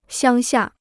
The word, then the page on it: 乡下: xiāng xia